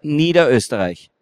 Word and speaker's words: Niederösterreich: Niederösterreich.